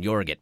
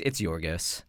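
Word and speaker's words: Yorgos: It's Jorgos. – I was like, maybe there's a different pronunciation that I'm not aware of. Jorgit. – Nope, it's Jorgos.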